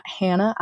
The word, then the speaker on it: Hanna: So my Instagram is at Hanna I.